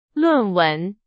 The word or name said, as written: 论文